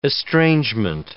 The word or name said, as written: estrangement